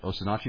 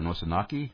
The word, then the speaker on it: Osinachi: do you think that's the way to pronounce it, Osinachi or Nosinaki? Or? – Osinachi, yes.